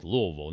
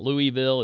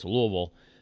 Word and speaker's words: Louisville: Louisville, you have to say it like you have a mouthful of pennies. Louisville, not Louisville. It's Louisville, according to the people who live down in Kentucky.